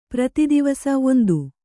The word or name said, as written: ಪ್ರತಿದಿವಸ